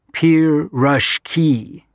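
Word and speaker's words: pir-rush-ki (пирожки): meat-filled or carrot-filled or cabbage-filled proshki, pir-rush-ki, thus pronounced, thus celebrated here, forever and ever.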